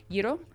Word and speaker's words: gyro: Gyro? So people say gyro. – Okay, so it's gyro. How do you say gyro? You don't really hear the G. – Gyro. Like saying year.